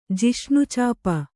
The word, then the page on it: ಜಿಷ್ಣುಚಾಪ: jiṣṇu cāpa